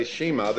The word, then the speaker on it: Shema: Well, we're gonna if you have a write this somewhere, remember it, because from now on when I say Shema, this is what's gonna be. It's pronounced in this room, and as long as I am head of this organization, it's pronounced Shema.